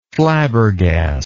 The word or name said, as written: flabbergasting